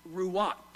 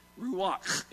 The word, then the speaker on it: ruach: It is ruach, ruach. And you kind of have to say it like you got a piece of popcorn stuck in the back of your mouth. You know that ruach.